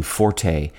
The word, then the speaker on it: forte: Incidentally, forte has its counterpoint in the word foible, F-O-I-B-L-E, meaning both the weakest part of a sword blade and a person's weak point. There is some controversy over how to correctly pronounce this word. Common choices in American English are forte and forte, but many usage commentators recommend rhyming it with fort.